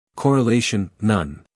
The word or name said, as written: correlation